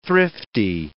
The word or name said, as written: thrifty